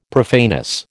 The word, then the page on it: profaneness: American English